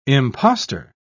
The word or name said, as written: impostor